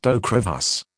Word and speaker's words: Ocrevus: This is the consumer medicine information for Ocrevus, pronounced Ocrevus.